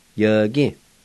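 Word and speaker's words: yvgi: Then it's pronounced y-gi.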